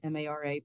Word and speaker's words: Mara: like Mara, M A R A, but it's pronounced more like the Irish word for sea.